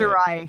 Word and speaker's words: Shirai: Io Shirai, I believe, is how you say Shirai. – It. Shirai.